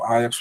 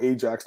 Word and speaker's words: Ajax: We call it Ajax, not Ajax.